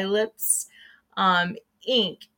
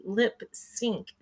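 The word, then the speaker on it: Lipps Inc.: All right, we're going to move on while I look that up to Funky Town by Lips Inc. And it's, and I never knew this. I keep saying Lips Inc. and it's actually supposed to be Lip Sync. That's how you're supposed to pronounce it.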